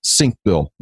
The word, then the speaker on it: Senkbeil: The author's name is Harold Senkbeil. That's exactly how you pronounce it, and there's no question about it.